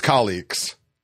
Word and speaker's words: Calix: Okay, so yeah, I say Calyx as well. – Okay, Calyx. – Now I know it's colleagues.